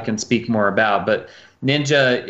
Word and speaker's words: NYNJA: And then last year I met the founders of Ninja. It's spelled NYNJA.